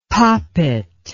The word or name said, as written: popit